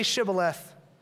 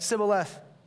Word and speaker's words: Shibboleth: When he said no, they said to him, "Then say Shibboleth." And he said, "Sibboleth," for they could not pronounce it right.